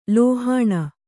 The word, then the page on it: ಲೋಹಿನಿ: Kannada